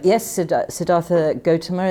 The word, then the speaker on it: Siddhartha Gautama: Am I right in calling him Siddhartha Gautama, or is there a better way to pronounce it? – You could call him, Siddhartha Gautama is fine.